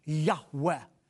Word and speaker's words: Yahweh: Most Semitic scholars feel that God's personal name, which comes from the four Hebrew letters, Yud, He, Vav, He, is pronounced a breathy Yahweh.